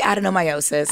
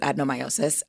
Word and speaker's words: adenomyosis: I say adenomyosis. – Adenomyosis. Okay. – Yes. Our IVF doctor, you know, pronounces it slightly different. – Okay. – But I pronounce it adenomyosis, but it's endometriosis of the muscle.